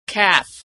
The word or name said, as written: calf